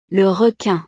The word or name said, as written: le requin